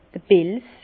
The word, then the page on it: d'r Pelz: Bas Rhin; Herrlisheim